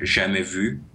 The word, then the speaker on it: jamais vu: in with a French accent, it would be pronounced jamais vu, but jamais vu is completely fine.